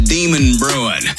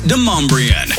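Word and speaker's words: Demonbreun: Hey, just so y'all know, the street's not called Demon Bruin. It's pronounced Demombrian.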